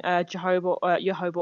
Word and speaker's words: Jojoba: Jojoba oil, as it's pronounced, because that is a very close match to the way that the natural oils of our skin are constructed anyway.